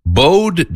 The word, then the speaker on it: bowed: Bowed down, the definition of that, let me look at it. It's pronounced bowed when it means like you're bowing, like you're waiting.